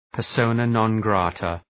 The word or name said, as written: persona non grata